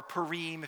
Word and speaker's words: Purim: Purim, or Purim if you want to be, you know, hoity-toity and do broader pronunciation, but Purim is really what they call it.